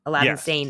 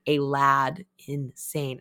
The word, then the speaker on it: Aladdin Sane: which it's spelt like Aladdin and the Magic Lamp, Aladdin, but it's like a lad insane.